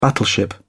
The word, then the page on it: battleship: British English